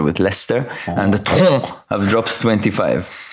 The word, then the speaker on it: Troyes: How do you pronounce T-R-O-Y-E-S? – Say it again. – T-R-O-Y-E-S. – Troyes. – I would guess they're a French team. So, Troyes. Okay, let's call them Troyes.